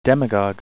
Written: demagogue

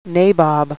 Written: nabob

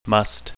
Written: musth

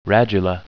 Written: radula

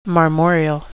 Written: marmoreal

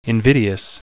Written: invidious

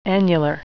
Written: annular

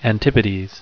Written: antipodes

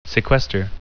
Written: sequester

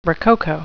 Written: rococo

